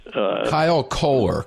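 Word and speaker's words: Kyle Koehler: Kyle Kohler, K-O-E-H-L-E-R, Republican from Springfield, not far outside Dayton.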